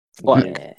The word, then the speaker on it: Flick: Flick.